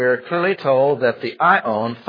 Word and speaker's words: aion: The word age is the word ion, A-I-O-N, pronounced ion.